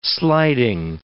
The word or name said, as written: sliding